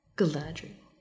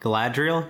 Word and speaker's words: Galadriel: Galadriel? Yeah, that is how it's pronounced in the movies.